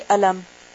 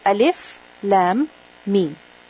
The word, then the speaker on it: ألف لام ميم: And each of them is read, it is pronounced separately. In the sense that you don't say ألم. You don't say ألم. How do you say it? ألف لام ميم.